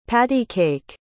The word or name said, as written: patty-cake